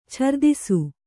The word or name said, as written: ಛರ್ದಿಸು